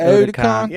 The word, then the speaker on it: Otakon: But let me ask you, what is Otakon? Is that how you pronounce it? – It's Otakon. – Yeah, that's right.